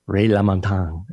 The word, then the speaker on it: Ray LaMontagne: Ray LaMontagne, if I'm saying that right.